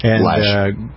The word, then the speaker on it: Loesch: You have host Radio America is our originating network, and you have other people like Dana Loesch and Lash. – Well, I always pronounce it. Why do I? It's spelled. – It looks Loesch. Yeah, L-O-E-S.